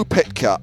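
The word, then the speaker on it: Pytka: Is it Piat? – It's Joe Pitka, and I'm not going to give you the point because, quite frankly, I don't think you're going to need it.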